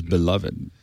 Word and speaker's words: beloved: There's lovable, and then there's beloved. Which is higher? I don't know. I don't know which is the higher. – I'm 3-syllable position. Beloved, by the way.